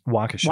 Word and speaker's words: Waukesha: Well, looks like your stream's going to be mainly about Waukesha. Is that how you say it? – Waukesha.